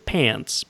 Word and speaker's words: K-Pants: A band called, they pronounced it Pants, but it's spelled K-Pants, K-P-A-N-T-S. I guess the name came from an episode of that Chris Elliott show, Get a Life, where he learned that the real spelling of Pants was with a silent K.